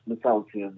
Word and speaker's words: Mata'utai: Is it Peter Matoudia, or are you still Matoudia, or what are you going under? – No, it's Matoudia.